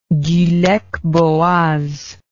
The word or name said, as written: gulek bogaz